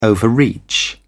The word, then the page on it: overreach: British English